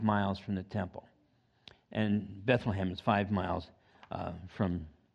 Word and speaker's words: Bethlehem: Uh, in John, if you want to turn to that, chapter 6, And it's in- interesting because Bethlehem, if you're pronouncing it in the, in the Hebrew, it's Bethlehem, and it literally means house of bread.